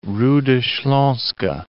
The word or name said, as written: Ruda Slaska